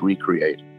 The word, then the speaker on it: recreation: The word recreation, if it's pronounced properly, is recreate.